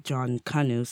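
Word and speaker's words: Junkanoo: Then, like, within Jamaica, it's slightly different. It's pronounced like John Canoe, so the name John and then Canoe.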